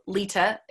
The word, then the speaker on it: Leta: My name is spelled Lita. It's L-E-T-A.